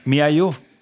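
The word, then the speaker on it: miHoYo: Se, se pronuncia Miayo. Miayo.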